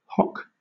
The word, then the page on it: hock: British English